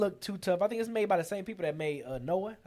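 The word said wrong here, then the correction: Nioh: I think I'm saying that right. – Neo. – Yeah.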